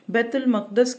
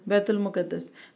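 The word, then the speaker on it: बैतुल मक़दस (بيت المقدس): रिवायत है हज़रत मास बिन जबल से, कहते हैं कि रसूलुल्लाह सल्लल्लाहु अलैहि वसल्लम ने फरमाया बैतुल मक़दस की आबादी, अच्छा बाज़ लोग इसको क्या पढ़ते हैं मुकद्दस, बैतुल मुकद्दस, हमारा उर्दू वालों का प्रोनाउंसिएशन काफ़ी ख़राब है अरबी में कुछ लफ्ज़ और उर्दू में मुश्तरक होने की वजह से।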